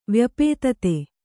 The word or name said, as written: ವ್ಯಪೇತತೆ